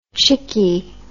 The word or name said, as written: chickie